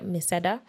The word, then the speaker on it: Miseda: Guys, listen to Odyssey by Miseda.